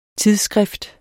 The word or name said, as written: TIDSSKRIFTETS